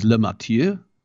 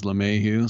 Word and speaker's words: LeMahieu: And first I had to figure out how he pronounced his name because he's Le Mathieu, but he says LeMahieu.